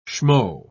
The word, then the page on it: schmo: British English